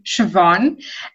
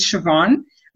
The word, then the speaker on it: Siobhan: So you pronounce my name, Siobhan. And over the years, because so many people struggle with it, I always introduce myself as Siobhan, but you can call me V because most people do it and it's just easier to remember. – Okay. But yeah. Your spelling of your name is S-I-O-B-H-A-N. So that B-H is pronounced as a V, Siobhan.